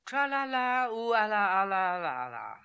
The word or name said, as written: Tralalaooalalalala